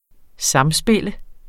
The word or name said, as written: samspil